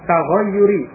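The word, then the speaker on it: taghayyur (تغيّر): Dengan tasyir. Afwan. Bukan tasyir ini. Taghayyuri. Jadi ini tulisannya yaknya hanya satu. Ya, membacanya taghayyur. Taghayyurun. Kalau ianya dua itu taghyir. Berbeda ya. Ini perhatikan tulisan dan ucapannya, bentuknya. Taghyirun, ini ianya dua. Tapi kalau di situ ianya satu. Berarti membacanya taghayyur. Berbeda.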